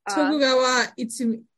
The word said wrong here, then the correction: Iemitsu: Iemitsu. Iemitsu.